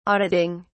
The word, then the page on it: auditing: British English